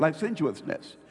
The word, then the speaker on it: licentiousness: How do you say it? Licentiousness. That's it, licentiousness.